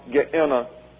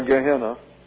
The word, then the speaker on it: Gehenna: and the sentence of Gehenna or Gehenna. Let's take a look at this. Gehenna is the Greek pronunciation. Gehenna is the anglicized version.